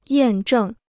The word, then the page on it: 验证: yàn zhèng